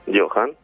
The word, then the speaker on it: Johan: ¿Cómo es tu nombre? – Johan. – Lo dije bien. – Johan. – Johan. – Yo no dije nada, no puedo. – Como Cruyff. – En ningún momento. – Como Cruyff. – Como Cruyff, pero sí, sin talento, digamos, claro, para el fútbol.